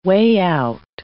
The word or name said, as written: way-out